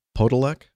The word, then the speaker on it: Podolec: Tom Podolec, I think is the way you pronounce his name, P-O-D-O-L-E-C. And this is Tom Podolec Aviation, and at T-O-M-P-O-D-O-L-E-C.